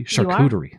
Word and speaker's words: charcuterie: Am I pronouncing that correctly? – Charcuterie. That's you can be fancy with these charcuterie boards furniture, and more.